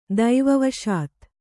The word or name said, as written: ದೈವವಶಾತ್